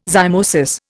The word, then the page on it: zymosis: Indian